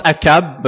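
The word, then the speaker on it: Achab: Et on prononce Akab, parce qu'apparemment qu'en hébreu, le CH, ça doit se prononcer K.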